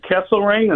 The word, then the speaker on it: Kesselring: And Kesselring, is that how you say it? – Yep.